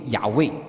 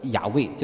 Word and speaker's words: YHWH: 所以 YHWH 的正确发音很早就失传了，但是许多现代学者相信其正确的发音接近于雅味就是。所以现在很多你可以看到就是一些注释的话，就是雅味，